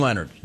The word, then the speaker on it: Leonhard: You know it's Jim Leonhard.